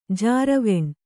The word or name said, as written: ಜಾರವೆಣ್